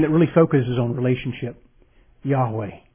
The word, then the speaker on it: Yahweh: It's the name Yahweh, or it used to be pronounced Jehovah, a German pronunciation.